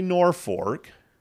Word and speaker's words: Norfolk: Is that how the locals say it? – The locals say Norfolk. We're just teaching you all kinds of stuff. The locals say Norfolk. I always still say Norfolk. But Norfolk. – Yeah, if you want to sound like you really know what you're talking about, you say Norfolk.